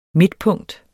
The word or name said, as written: midtpunkt